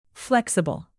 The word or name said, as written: flexible